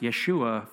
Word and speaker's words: Yeshua: Joshua in Hebrew is Yehoshua, meaning God is deliverance or God saves. Nowadays it's pronounced Yeshua for short.